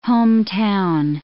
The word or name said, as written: hometown